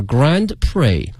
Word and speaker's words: Grand Prix: Formula One， F-O-R-M-U-L-A， Formula 后面加 One，然后那个大奖赛叫 Grand Prix。这个词啊有些怪异哈，前面这个 Grand 没有问题 G-R-A-N-D，但是呢这个大奖啊它的拼写呢是 P-R-I-X，但是在发音的时候呢后面这个 X 是不发音的，直接念的什么 Prix 就行了哈，所以呢叫 Grand Prix，每年举办 is held every year。